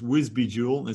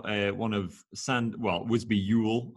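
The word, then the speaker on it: Visby Jul: This one is Wisby Jewel. It's got Wisby Yule, I suppose would be the proper pronunciation. It's got one of Santa's like gnomes or elves on it. Yule in Swedish means Christmas.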